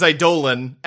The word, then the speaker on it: Eidolon: The correct pronunciation is Eidolon, and I will not be saying it that way.